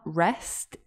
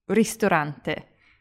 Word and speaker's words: ristorante: In Italian, it's not rest, it's rist, with an I, ri, rist, ristorante.